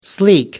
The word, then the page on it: sleek: American English